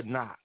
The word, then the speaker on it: knock: Knock or knock. Again, the K is silent.